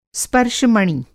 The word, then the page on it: स्पर्शमणि: स्पर्श-मणि; Hindi